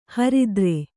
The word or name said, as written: ಹರ್ತಾರ